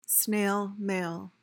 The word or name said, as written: snail mail